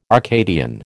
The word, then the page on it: Arcadian: American English